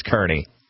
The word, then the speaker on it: Kearney: You just said Kearney, so I'm going to guess Kearney. – Kearney. – Kearney! Like a carnival barker.